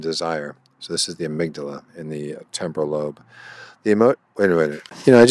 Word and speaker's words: amygdala: The amygdala, pronounced amygdala, albeit a small almond-shaped portion of the brain, is important in emotional function.